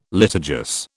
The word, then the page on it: litigious: British English